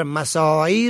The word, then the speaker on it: مساعی: و کلمه دیگر مساعی را بدون عین می خوانند و تلفظ می کنند و در مواردی حتی بدون عین می نویسند که درست نیست. ریشه کلمه سعی است.